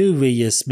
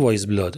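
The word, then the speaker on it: Weyes: یعنی اگه اسمش رو اشتباه بخونی میشه ویس بلاد تلفظ درستش ولی همون وایز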